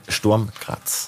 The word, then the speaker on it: Sturm Graz: 2,5 Millionen Euro für Ilzer und seine Co-Trainer, zwei nimmt er ja mit, mindestens von Sturm Graz. – Das ist die richtige Betonung, deshalb auch Sturm Hoffenheim.